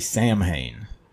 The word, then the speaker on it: Samhain: I say Samhain. It's S-A-M-H-A-I-N, like the fucking Glenn Danzig band, right? But it's pronounced Samhain, right?